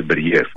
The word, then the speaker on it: Μπέριεφ: Μπέριεφ, Μπέριεφ, πού τονίζεται πρώτα απ' όλα για να το λέμε και σωστά. – Νομίζω το λένε Μπέριεφ.